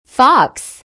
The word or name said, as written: fox